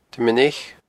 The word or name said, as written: Druim an Eich